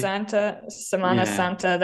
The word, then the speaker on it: Semana Santa: During Semana Santos, I think I pronounced that correctly. – Semana Santa, that's Easter week.